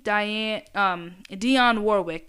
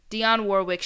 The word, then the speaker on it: Dionne Warwick: And also, I believe Dionne Warwick, that's how you say her name, Dionne Warwick, she announced that she, I think, is planning a biopic of her own with Tiana Taylor set to direct and star.